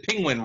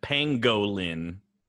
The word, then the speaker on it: pangolin: You said penguin wrong, Benedict Cumberbatch. – Yeah, I mean, you know. – Spell it. Do it phonetic. Pangolin. – Pangolin. – There you go.